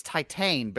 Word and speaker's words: Titane: Yeah, that is that's the exact spot of my list, I think it's titane because it's titane? Their word for it's the French word for titanium. – That's right. I looked it up on like Google Speak or whatever, and it said Titan. That is how you say it is titane.